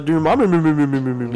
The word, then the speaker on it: Dormammu: Dormammu. As fucking Deadpool calls out Dormammu.